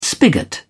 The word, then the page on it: spigot: British English